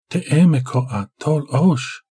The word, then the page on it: Temekoa-Tolozh: Galindri